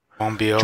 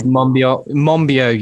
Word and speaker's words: Monbiot: I think it's George Monbiot. – Monbiot. – George Monbiot, yeah, okay, yeah.